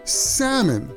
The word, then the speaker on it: salmon: Sometimes English is pronounced differently than it's written. The correct pronunciation is salmon. The L, okay. – L is silent.